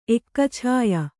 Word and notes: ಎಕ್ಕಛಾಯ: Kannada

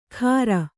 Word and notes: ಖಾರ: Kannada

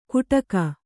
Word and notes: ಕುಟಕ: Kannada

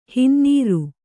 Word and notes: ಹೊನ್ನಾರು: honnāru

